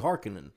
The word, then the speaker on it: Harkonnen: It's Harkonnen, right. But it's not. It's actually Harkonnen.